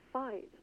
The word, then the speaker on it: fight: But the words bavelkomd and fight are English words. And the word fight is to rhyme with geit or gate in another pronunciation.